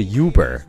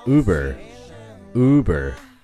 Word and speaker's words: Uber: 那优步真正的读音并不是 Uber，而是 Uber，Uber。前面的字母 U 发的是一个长的 U 的音，Uber，Do you understand？